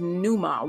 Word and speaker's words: pneuma: And then in the Greek, it's pneuma, wind, breath, or spirit. And in the Greek, you spell that P-M-E-U-M-A. And it sounds like ruah, almost like the sea is silent.